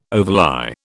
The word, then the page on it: overlie: British English